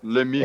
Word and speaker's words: Lemieux: é a questão do Shane Lemieux, né? Sim. De acordo com. Acho que é assim que se pronuncia. – Lemieux. – Lemieux. É, é Lemieux.